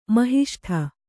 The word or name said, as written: ಮಹಿಷ್ಠ